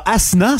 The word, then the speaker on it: Asshna: A-S-S-H-N-A. – Avec un H. – Oui. – Mais on prononce pas le H. – Ben oui, puis non. – OK. – Ah. Prononce-nous les dons, mettons, pour être sûr. – Asna. – Ouais, c'est ça. Il a dit Asna, lui. – OK, tu fais le ch.